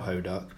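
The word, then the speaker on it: Hoduck (호떡): which are donuts called Hoduck. They're not spelt Hoduck, but that's how it's pronounced.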